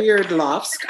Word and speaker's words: Sverdlovsk: 10 hikers all but one were students at the Urals Polytechnic Institute in Sverdlovsk. I'm going to go with that pronunciation. – I like it. Sverdlovsk. – I like it. – Sverdlovsk. All right. That sounds good, right?